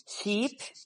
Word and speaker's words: sheep: Por esta razón, no es lo mismo decir ship o sheep. Ship es barco, sheep es oveja.